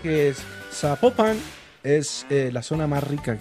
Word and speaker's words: Zapopan: Oiga, nos escriben, nos escriben los oyentes y que no se, no se pronuncia Zapopan, sino que se pronuncia Zapopan. – Ah, Zapopan. – Ah, bueno, Zapopan.